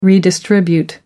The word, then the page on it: redistribute: American English